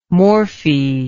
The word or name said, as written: morphy